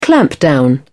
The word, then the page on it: clampdown: British English